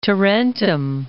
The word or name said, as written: Tarentum